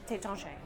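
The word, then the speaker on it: Taittinger: I love Tétanché. Am I saying it correctly? – Tétanché. – Yeah.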